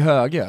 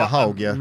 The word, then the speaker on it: Hauge: Ja, fast jag hörde Hauge själv säga Hauge. – Alltså, alla i Norge säger Höge. Han sa själv Hauge. Han tog en kolasinats med uttalet sitt eget namn fel för att eventuellt göra det mer internationellt.